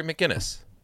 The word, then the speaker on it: McGinnis: Am I getting that name right? – We're getting the last name wrong. Now we're both going to Google, right? Batman Beyond. – Yeah, I'm pulling from... No, it's Terry McGinnis.